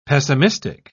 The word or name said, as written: pessimistic